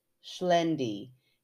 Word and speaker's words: Xlendi: We arrive at, oh, and I'm so glad she gave me the pronunciation of this because it's spelled X-L-E-N-D-I. – Oh my. – Yeah. So I saw that, like, my eyes, when I first read it, my eyes got really like, oh no. And then she said, pronounced Schlendi, if that helps.